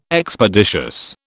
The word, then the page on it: Expeditious: British English